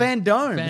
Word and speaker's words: FanDome: No, it's Fandome. It's like the dome.